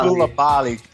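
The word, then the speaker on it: Coulibaly: È vero che lo pronunciano Kulabali.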